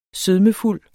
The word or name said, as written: sødmefuld